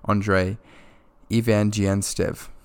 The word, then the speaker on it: Andrey Zvyagintsev: this is a Russian name, so forgive me for my pronunciation, but I believe that it's pronounced Andrey Zvyagintsev.